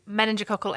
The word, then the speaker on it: meningococcal: Meningococcal ACWY vaccine.